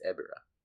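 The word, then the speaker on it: Ebirah: Who was intended to fight Ghibra in Ghibra Horror of the Deep, the 1966 movie? – Ghibra? – How do you pronounce it? – There's no G. It's Ebira.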